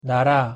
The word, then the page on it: 나라: Korean